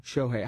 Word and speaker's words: Shohei: Shohei. How do you spell that? It's like Shoei, isn't it? – Mm-hmm.